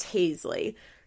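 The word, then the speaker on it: Hazelee: Is the baby pronounced Hazley? It's H-A-Z-E-L-E-E, right? Yeah, it's Hazley.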